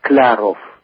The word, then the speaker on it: Sklaroff: In any event, the first one is the name Sklarov, S-K-L-A-R-O-F-F, And the town of origin is Kamenka, near Cherkasy, Ukraine. – Okay. The name in Russian, the pronunciation is Sklarov. – Sklarov, okay. – It's not far from what you tried, I think. – Sklarov. – Sklarov. – Sklarov. – So with the soft L, very soft L to be pronounced. It's Skla, but Skla.